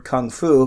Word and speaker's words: kung fu: But I think the correct way to pronounce it is very similar to how you actually pronounce the word kung fu, because we also mispronounce that because we're stupid Americans. – Kung fu. – Yeah, it's like a hard G with a K. Kung fu. – Yeah, it's like a G-U. – Yeah, it's like you ever watch old videos of Bruce Lee? You can he pronounces it correctly.